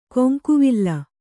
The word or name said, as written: ಕೊಂಕುವಿಲ್